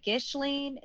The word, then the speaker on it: Ghislaine: Is it Ghislaine? Is it... – So I looked up a YouTube video about how to say it. And it's like the H-I-S in the middle is silent. So it's Ghislaine.